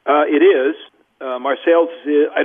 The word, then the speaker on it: Marseilles: It is, it is located in Marseilles, Illinois, spelled the same as Marseille, France, but pronounced differently.